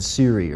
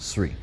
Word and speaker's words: Sree: A number of people will put an SH sound in there and say Ted Sree. So I can always tell when the telemarketers are calling. But basically, if you want to pronounce my name correctly, it's like this. It's really easy. It's 1, 2, 3. So there's no SH sound in it, so it's just Sree.